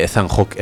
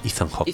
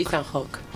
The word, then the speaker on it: Ethan Hawke: Como hice la sección de Alex y pronuncié mal un nombre y dije Ethan Hawke, es Ethan Hawke. – Ethan Hawke.